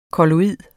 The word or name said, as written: kolloid